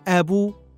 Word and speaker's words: أب: وقل يد فم دم أخ أب بغير تشديد فذاك المذهب آه كل هذه الكلمات تنطق هكذا مخففة من غير تشديد فذاك المذهب يعني هذا والمذهب المعتمد الصحيح الفصيح الذي عليه العرب لماذا؟